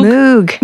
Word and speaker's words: Moog: But as we know, he was very much obsessed with the Moog. – Moog. – Dang it! I always say it wrong.